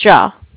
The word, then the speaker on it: Jia: Sure, it's Ja. J-I-A. – Ja. J-I-A.